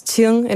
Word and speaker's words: Qing: Dat is Qing in het Mandarijn. En er stond dan, door heel veel leraren wordt het uitgesproken als King, want die weten niet wat ze ermee moeten.